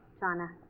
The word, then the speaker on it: Jana: I apologize for maybe mispronouncing your first name earlier. Jonna. – Jonna. – But it's spelled J-A-N-A, right? – Right.